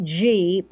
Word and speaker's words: ghee: I call it G, but I spell it G-H-E-E. It's like G.